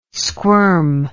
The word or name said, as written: squirm